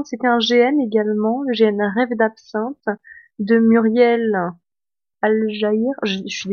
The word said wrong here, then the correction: Algaïres: Alors, il me semble que la prononciation est Algaïres.